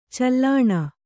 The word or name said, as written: ಚೀಲಣ